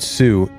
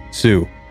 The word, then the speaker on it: Sioux: The Ojibwe, who were feuding with the Lakota, called their rivals a word meaning cutthroat, which to English speakers sounded like Sioux. And when the English speakers wrote the Ojibwe word for cutthroat down, they spelled Sioux like a French word, with a silent X.